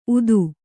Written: ಊದ

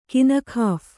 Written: ಕಿನಖಾಫ಼

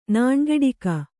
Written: ನುಣ್ಗಟಕಿ

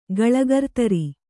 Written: ಗಳಗರ್ತರಿ

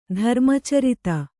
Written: ಧರ್ಮಚರಿತ

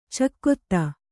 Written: ಚಕೋತ್ತು

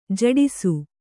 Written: ಝಡಿಸು

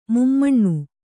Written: ಮುಮ್ಮನ

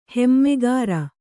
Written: ಹೆಮ್ಮೆಗಾರ

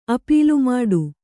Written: ಅಪೀಲುಮಾಡು

